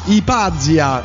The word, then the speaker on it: Ipazia: Ipa, Ipazia, con l'accento sulla prima A, era un grande scienziato, una grande scienziata uccisa dai suoi detrattori, Ugo.